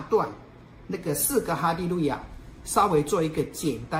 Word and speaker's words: 哈利路亚: 如果把以哈利路亚为主体，那是赞美神嘛，啊，哈利路亚，那本来是希伯来音啊，哈利路亚就是赞美耶和华啊，赞美真神，哈利路亚啊，本来是希伯来音，但是他用希腊文发音啊，就是哈利路亚啊。